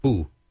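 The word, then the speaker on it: fu (福): Okay, I believe it's pronounced fu in Chinese, happiness.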